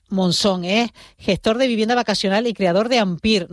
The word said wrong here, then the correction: Ampira: No sé si lo he dicho bien, Yacín. Buenos días. – Buenos días, ¿qué tal? Sí, Ampira, sería Ampira, ¿qué tal?